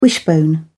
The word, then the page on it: wishbone: British English